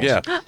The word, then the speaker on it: Druig: There are some people who think that Kogan, I always mispronounce, I can't know his name, Druig from Eternals. – Druig, yeah.